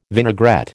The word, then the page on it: vinaigrette: American English